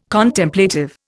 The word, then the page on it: Contemplative: Indian